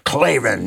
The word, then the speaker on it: Klavan: And you got to say it like that. You say, Klavan, yeah, yeah, I know.